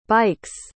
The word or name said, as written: bike's